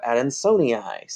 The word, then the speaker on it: Adansonii: Adansonii is named after Adanson or Adanson. So Mr. Adanson, so Adansonii. So you would kind of like say it like their name.